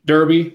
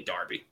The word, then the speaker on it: Derby: Matt and Derby, which is how it's spelled. It's really Darby. It's okay. – It's Darby. D-E-R-B-Y.